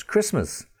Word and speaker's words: Christmas: You've got M-O-R-T-G-A-G-E, but no T sound. Mortgage. And of course, Christmas. What would be Christmas without a tree, but there's a T there and it's a silent T. So the Christmas tree, but Christmas with the T and the T is silent. C-H-R-I-S T-M-A-S, Christmas.